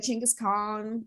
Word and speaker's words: Chinggis Khan: I don't know, like maybe like Chinggis Khan. Like I think that would be cool. Apparently, that's the correct way to pronounce it, according to my like angry Korean friends.